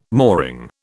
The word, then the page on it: mooring: British English